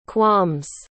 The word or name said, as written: qualms